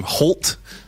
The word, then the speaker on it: HXLT: And this is a song by a guy named Holt. It looks like it's HXLT, but it's pronounced Holt.